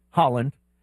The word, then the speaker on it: Haaland: Haaland. Say it like the country, but it's spelled a little differently.